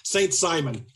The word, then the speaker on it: Saint-Simon: And he essentially understood Monsignor Ryan understood social justice in the sense that was used by Henri de Saint-Simon, Saint-Simon, I'll go with the English pronunciation, and Henry George, which the essence of it is that the end justifies the means.